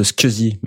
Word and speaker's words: SCSI: Alors moi, je prononce Scusi, mais je les je me souhaite Squeezie, Peu importe. les gens disent CSI l'acronyme c'est SCSI.